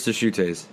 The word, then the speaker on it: Deschutes: Deschutes. I'm pretty sure it's Deschutes. – Deschutes. We'll say it. Hashtag not sponsored. Yeah, I'm drinking Deschutes Black Brute.